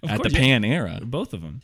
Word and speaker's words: Panera: It's actually pronounced Panera. – Panera. – Panera. – Panera. – Yeah, like it's Pan-era bread, like the god Pan.